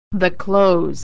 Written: the clothes

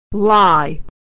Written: lie

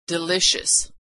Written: delicious

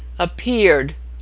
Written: appeared

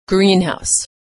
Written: greenhouse

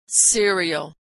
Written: cereal

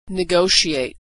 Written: negotiate

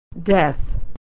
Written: death

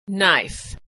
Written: knife

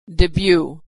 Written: debut

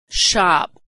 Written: shop

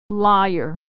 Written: liar

